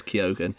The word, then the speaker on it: Keoghan: Sorry, I found out how to pronounce Barry Keoghan. It is Keoghan.